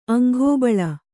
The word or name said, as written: ಅಂಘಾಬಳ